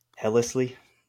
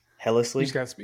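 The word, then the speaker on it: Helsley: How do you say his name? – Hellesley. – Hellesley? – Hellesley. – You got spelled wrong in the notes. That's why. – Oh, yeah. – That's why you're pronouncing it that way.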